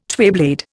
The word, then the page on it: twayblade: Indian